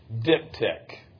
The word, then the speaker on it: diptych: But he has, throughout his book, referred to Zechariah as a diptych. I'm not sure I'm pronouncing that right, but the pronunciation guide on Google sounded like it was two short I's. It's spelled D-I-P-T-Y-C-H.